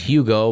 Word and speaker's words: Hugo: That's normally pronounced Hugo, but for some reason the Spanish language abandoned the letter H some time ago.